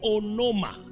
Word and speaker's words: onoma: We said in the Greek that the word name here is the word onoma. O-N-O-M-A.